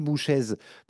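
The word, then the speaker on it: Bouchez: vous dites à ah, cette, pauvre Elodie Boucher, mais euh, moi, j'ai toujours prononcé Elodie Bouchèze.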